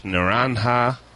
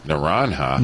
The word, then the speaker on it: naranja: because the Spanish word for it is naranja. And, you butchered that. – Naranja. – Naranja. – Naranja. – There you go.